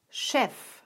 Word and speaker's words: Chef: Noch häufiger wird CH am Anfang aber wie Sch ausgesprochen. Zum Beispiel bei den Worten Chance und Chef.